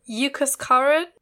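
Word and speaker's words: Huascarán: Yucascaran in Peru. It begins with a H, but it's pronounced like a U, so...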